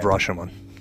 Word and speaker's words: Rashomon: Yes, I love Rashomon.